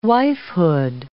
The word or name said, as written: wifehood